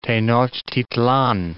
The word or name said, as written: Tenochtitlan